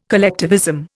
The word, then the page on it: collectivism: Indian